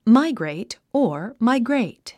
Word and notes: migrate: mǽiɡreit; マイグレェイト

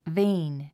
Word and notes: vain: véin; ヴェイン

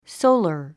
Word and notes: solar: sóulər; ソォラァ

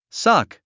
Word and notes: suck: sʌ’k; サク

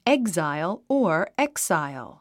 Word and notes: exile: éɡzail; エグザァイォ

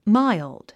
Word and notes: mild: máild; マァイルド

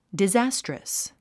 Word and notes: disastrous: dizǽstrəs; ディザァストラス

